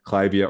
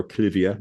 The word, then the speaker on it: Clivia: Clivia or Clivia is the way the British pronounce it.